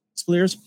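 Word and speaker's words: Spallier: You say, as for Jack Crusher, played by Downton Abbey's and Outlander and Aragon's Ed, is it Spaliers? Am I saying that right? – Yeah. – Spaliers.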